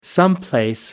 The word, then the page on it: someplace: American English